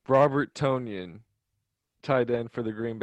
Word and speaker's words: Tonyan: So, first off, I believe his name is pronounced Tonyan. – Tonyan, like an onion, okay.